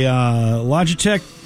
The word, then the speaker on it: Logitech: It's a Logitech, am I saying that right, Sean? – Yeah.